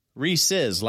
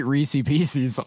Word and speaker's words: Reese's: Reese's, like possessive. – Ah, get fucked, dude. Reese's. It's because it's not Reese's Pieces. Dude, nobody's eating candy that rhymes with shit. – Dude, I eat Reese Pieces all the time.